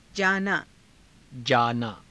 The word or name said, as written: jaanaa